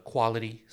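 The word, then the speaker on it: Kwality: You're going to go to Quality Sweets, spelled with a K, pronounced Quality.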